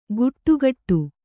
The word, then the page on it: ಗುಟ್ಟುಗಟ್ಟು: guṭṭugaṭṭu; kannada